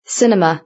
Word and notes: cinema: British English

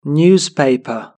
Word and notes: newspaper: British English

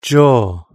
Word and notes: jaw: British English

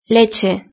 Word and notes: leche: Spain Spanish

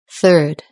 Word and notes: third: British English

